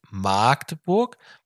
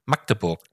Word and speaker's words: Magdeburg: Dieses Städtchen heißt nicht Magdeburg mit langgezogenem A, also nicht wie bei der Markt, sondern hier gilt ein kurzes A, wie bei Lack, also Magdeburg. Magde.